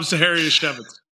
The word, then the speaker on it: Zaharyashevitz: Rob Zaharyashevitz. – See, and it rhymes with Manischewitz, so Zaharyashevitz. So that's the only way I can remember, because I thought it was Zaharyashevsky, but no, it's not that.